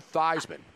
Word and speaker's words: Theismann: The great Joe Theismann, which rhymes with Heisman, joins us right now on Sirius XM 211.